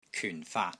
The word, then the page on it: KYUN4 FAAT3: Cantonese